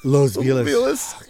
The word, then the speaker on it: Los Feliz: Los Feliz. – Did I say it wrong? – Feliz, yeah.